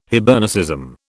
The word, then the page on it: Hibernicism: British English